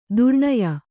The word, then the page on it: ದುರ್ಣಯ: durṇaya